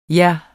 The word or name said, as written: Jer